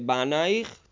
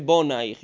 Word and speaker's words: Bonaich: Si scrive Banaich, tuoi figli, ma si legge Bonaich, i tuoi costruttori.